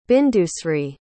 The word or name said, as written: bindusri